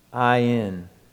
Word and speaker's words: ayin: It's pronounced ayin.